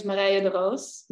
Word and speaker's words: Marije de Roos: So my name is Marije de Roos.